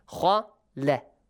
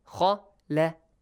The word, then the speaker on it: خالق: خالق. Two heavy letters, one light letter. خالق. خالق.